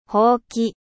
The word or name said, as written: balai